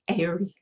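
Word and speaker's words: aerie: How do you pronounce an eagle's nest? Airy.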